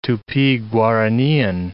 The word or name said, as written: Tupi-Guaranian